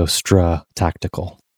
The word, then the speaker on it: StraTactical: So it's got that sort of American, instead of stra, it's stra, like American. So StraTactical is how we pronounce it.